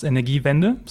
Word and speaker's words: Energiewende: And regarding Energiewende, it's pronounced Energiewende. So it's quite simple for a German word.